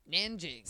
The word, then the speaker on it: Nanjing: It's just Nan and then Jing. – Nanjing.